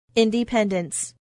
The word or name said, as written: independence